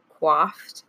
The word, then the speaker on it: coiffed: It's not like who has, I don't even know how to say this word, the perfectly coiffed hair. – Coiffed? – Yeah, that's right. No, you coiffed. Were right the first time.